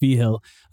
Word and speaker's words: Vigil: Mike, my last name is spelled like Vigil. It's pronounced V-Hill.